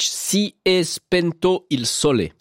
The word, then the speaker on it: Si è spento il sole: Adriano Celentano avec ce titre de 1962, choisi par Stefanie Ivanovic, Si è spento il sole. Ça se prononce exactement comme ça.